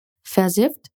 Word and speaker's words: versifft: Esse adjetivo tem dois Fs. E sempre que temos consoantes dobradas, a regra de pronúncia é que a vogal anterior às consoantes é curta e aberta. Então, o i de versifft é curto e podemos pronunciar ele de uma forma mais relaxada. Vamos praticar. Versifft.